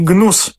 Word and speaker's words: Gnus: No i teraz trzecia audycja, która jest poświęcona programowi GNUS.